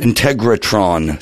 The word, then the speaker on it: Integratron: From the Integraton to Joshua Tree. – Close. – Integratron to Joshua Tree.